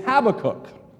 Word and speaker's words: Habakkuk: He informed the congregation that the correct pronunciation of the book that we're studying today is Habakkuk.